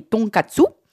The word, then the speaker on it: tonkatsu: Sinon, les Japonais aiment savourer des soupes, mangent des plats à base de viande, comme les tonkatsu. C'est du porc pané. – Magnifiquement fait. Hein. Prononcé, l'accent, nickel. – Je vais bientôt partir au Japon. Donc en fait, le tongatsu, c'est du porc pané.